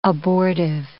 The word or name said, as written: abortive